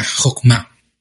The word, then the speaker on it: jokma: Eh, y de hecho, esta palabra de sabiduría, y tanto la que vamos a ver más adelante en Salmos, es una palabra hebrea que se, pronuncia jokma. Y esta palabra hebrea, jokma, eh, tiene otra traducción tiene un sinónimo a sabiduría, que es habilidad o pericia.